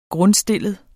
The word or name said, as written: grundstillet